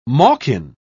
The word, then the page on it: malkin: British English